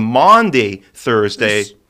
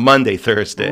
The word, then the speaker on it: Maundy Thursday: So from that mandate, that commandment, it has taken the name Maundy Thursday. It's spelled M-A-U-N-D-Y, not Monday Thursday.